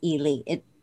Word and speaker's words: Ely: And it looks like Eli, but she's really from Ely.